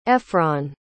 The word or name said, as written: ephron